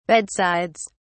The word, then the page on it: bedsides: British English